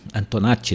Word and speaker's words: Antonacci: Ela faz um Antonacci, né, que é a pronúncia correta.